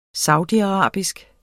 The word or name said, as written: saudiarabisk